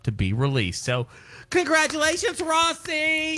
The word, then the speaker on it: Rhossi: Well, it's spelled R-H-O-S-S-I, so I could have, you know, the vowel and two consonants in between another vowel. I should have probably shortened that out to Rossi and Rossi. – Made it a long O, yeah.